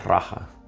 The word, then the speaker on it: raja: Em, enfocadas en meditación, pero eh, esta raya, yo lo pronuncio raya, raja, raja yoga, que es la meditación eh, eh, real, no real de que sea falsa o real, sino de realeza.